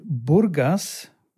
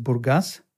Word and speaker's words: Burgas: Dieses Mal eine ganz besondere Ausgabe, denn du befindest dich gerade in Bulgarien, in Burgas, wie die Deutschen das aussprechen würden. Eigentlich heißt es Burgas.